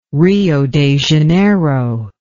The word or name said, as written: Rio de Janeiro